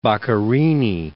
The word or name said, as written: Boccherini